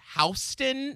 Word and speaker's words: Houston: it's spelled like Houston. – Like Houston, Texas. – Yes, but it's Houston. That's how you pronounce it. And they get really upset if you say it incorrectly.